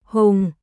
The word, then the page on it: Hùng: Vietnamese